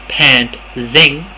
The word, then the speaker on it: pantsing: Now, it says, pantsing, pronounced pant-zing, from the Old English meaning to jerk someone's pants down in a crowded room.